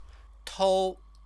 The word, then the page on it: tou: Chinese